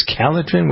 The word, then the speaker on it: Calotren: And as we're talking about losing weight, how about we spend a moment talking about Calotrin, which is it's spelled C-A-L-O-T-R-E-N. I always get comments from people, well, how do you spell this? It's Calotrin. What is the C-A-L-O-T-R-E-N?